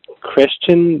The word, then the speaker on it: Cristian: You can search for my name, and my name is spelled Christian, but without the H. So C-R-I-S-T-I-A-N. And then my last name is spelled C-H-I-S.